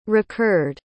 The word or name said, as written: recurred